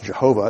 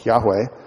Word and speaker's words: Yahweh: As I said, but the divine name isn't pronounced Jehovah. It's probably pronounced more like Yahweh.